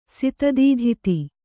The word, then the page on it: ಸಾಧಿತಧಾತು: Kannada